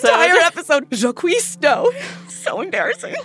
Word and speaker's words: Jacques: And they pronounced it like the French Jacques.